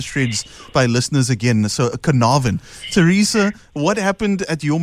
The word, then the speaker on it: Carnarvon: Time heals, Teresa, if I'm saying it correctly, is it Carnarvon, right? – Yes, it's Carnarvon in the Northern Cape.